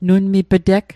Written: nunmip'teg

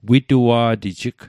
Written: witua'tijig

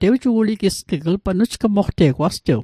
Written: nujgmoqteg

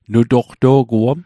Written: nutoqto'guom

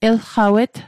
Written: elqawet

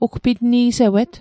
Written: ugpitni'sewet

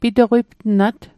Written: pito'qiptnat

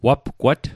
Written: wapgwat

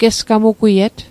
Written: gesgamugwiet

